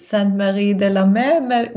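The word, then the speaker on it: Saintes-Maries-de-la-Mer: I might be saying that wrong. Did I say it right? Are you familiar with the place? – Yeah, I've been there, actually. I'm not really good at French, but Saint Marie de la Mer, maybe? – Yeah. Yes, that's it.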